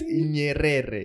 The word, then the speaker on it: Nyerere: Nyerere, how do you say it? – It's Nyerere. – Nyerere.